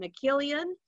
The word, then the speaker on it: McGillion: you go um, we also got uh, paul McKill- uh, oh gosh i'm going to say this wrong McGillion and he played the false uh, Frank.